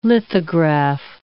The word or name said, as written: lithograph